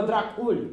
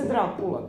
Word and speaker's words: Dracula: Ja rekao, kako kažu ovi u američkim filmovima Drakulj? Kaže, tako što ne znaju rumunski. Kaže se Dracula.